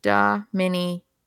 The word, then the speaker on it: Dominion: Dominion. well, now you're saying it completely wrong because, oh, wait. Oh, yeah, I'm saying it wrong. Dominium. Well, I was saying dominium for some reason.